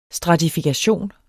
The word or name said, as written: stratifikation